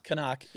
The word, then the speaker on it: CNOC: I think we're saying that right, aren't we? Canuck? – Yep. Canuck, yeah. – C-N-O-C.